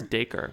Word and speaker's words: Dacre: Is that how you pronounce that? Dacre? – I think it's Dacre, yeah.